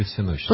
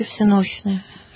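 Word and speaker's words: всенощное: А как вот, кстати, правильно, всеночное или всеночное? – По-церковно-славянски всеночное. – Всеночное. А мы, как правило, употребляем всеночное.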